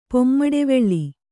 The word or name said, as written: ಪೊಮ್ಮಡೆವೆಳ್ಳಿ